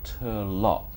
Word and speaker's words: Turlough: It's called, it looks like it's called Turlough, but it's pronounced Turlough.